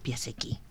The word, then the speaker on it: Piasecki: Si he pronunciado bien. – Sergiusz Piasecki. – Piasecki, muy bien.